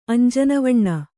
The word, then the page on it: ಅಂಜನವಣ್ಣ: kannada